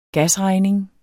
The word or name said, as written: gasregning